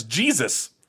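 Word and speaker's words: Geez's: It is spelled as written G E E Z apostrophe S, also known as Jesus.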